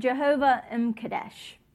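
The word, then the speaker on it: Jehovah M'Kaddesh: So Jehovah M. Kadesh. So you just kind of slur that M into the Kadesh word.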